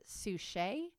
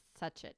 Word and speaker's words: Suchet: So I would pronounce it Suchet, but it could be Suchet.